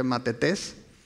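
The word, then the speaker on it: matetes (μαθητής): La palabra que se usa en la Biblia es esa, matetes, de hecho, se pronuncia matetes.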